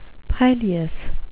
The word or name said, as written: pileous